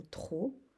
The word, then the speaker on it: trop: Ou encore dans le mot trop, par exemple j'ai trop mangé, on va marquer troupe. Il y aura ce P muet, mais qu'on est obligé d'écrire.